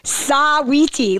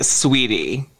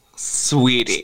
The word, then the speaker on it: Saweetie: I love her too, but I always say Saweetie. Like, I never say Sweetie. Is it pronounced Sweetie? I'm always like Saweetie. – You just have to say it like Sweetie. Sweetie.